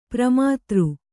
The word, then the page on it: ಪ್ರಮಾತೃ: pramātř